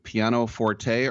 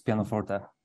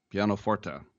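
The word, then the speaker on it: Pianoforte: He has directed a new documentary called Piano Forte, or do you say Piano Fort? How do you say it, Jakub? – It's from Italian, so it's Piano Forte. – Piano Forte.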